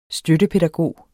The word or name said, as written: støttepædagog